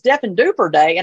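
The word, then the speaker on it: Diffendoofer Day: He said I mispronounced the title of the book, but I'm like, who knows how to pronounce half of Dr. Seuss's stuff, you know? But I had a first grader correct me and say, I think it's Deaf and Duper Day.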